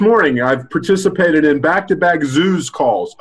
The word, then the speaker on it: zhuzh: The word is zoos, and that is spelled Z-H-U-Z-H. – Z-H-U-Z-H.